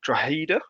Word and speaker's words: Drogheda: And as we've mentioned, I'm now joined by David Sheehan from LMFM Radio, the regular commentator for Drogheda, as it is pronounced, as I have double-checked, because, let's be honest, nobody knew.